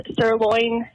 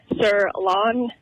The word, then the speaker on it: sirloin: I work in a restaurant, and it drives me crazy when people pronounce sirloin, sirlawn, or salmon, salmon.